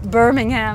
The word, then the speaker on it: Birmingham: How do you say that? – Birmingham. – Birmingham. Okay.